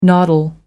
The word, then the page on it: noddle: American English